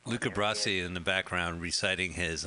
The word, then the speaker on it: Luca Brasi: Luca Brasi.